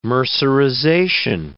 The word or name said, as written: mercerization